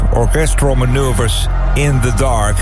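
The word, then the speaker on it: Orchestral Manoeuvres in the Dark: Het wordt vaak afgekort tot OMD. Maar de juiste uitspraak is Orchestral Maneuvers in the Dark.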